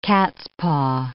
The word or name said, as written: cat's-paw